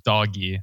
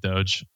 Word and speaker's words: Doge: It is literally named after a meme, which is the Doge meme or doggy, however you want to pronounce it. – Stick with Doge. You sound smarter. – Yeah, I'm sticking with Doge.